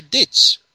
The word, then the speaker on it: Diets: developed out of this ditch. I mean, this dish. Dietz. – How do you spell it? – D-I-E-T-Z? – D-I-E-T-S.